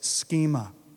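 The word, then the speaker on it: schema (σχῆμα): The other word that was not used is a word pronounced, well, it sounds phonetically like this, schema.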